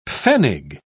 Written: pfennig

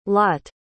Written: Lott

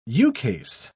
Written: ukase

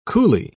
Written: coulee

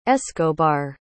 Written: escobar

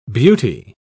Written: beauty

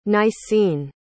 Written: nicene

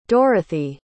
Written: dorothy

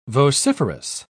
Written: vociferous

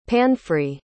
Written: panfry

